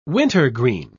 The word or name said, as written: wintergreen